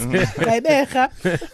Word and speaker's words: Gqeberha: Gqeberha.